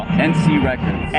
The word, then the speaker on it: NC Records: I mean, how do you pronounce that as well? – NC Records. – NC Records. – Yeah, it's short for Encinitas.